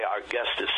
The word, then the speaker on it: Cortese: Now, a lot of times people, ladies and gentlemen, will spell Cortez C-O-R-T-E-Z. Okay, Cortese, C-O-R-T-E-S-E. So I'm sure there's people that maybe from old school will call him Gary Cortez. Or after you meet him and his wife and everybody else, you'll know that they actually pronounce it Cortese.